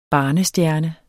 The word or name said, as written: barnestjerne